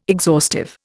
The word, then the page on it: exhaustive: Indian